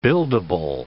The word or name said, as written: buildable